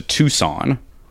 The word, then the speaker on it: Tucson: In keeping with pronunciation issues, did you just say Tuscan as opposed to Tucson? – I did.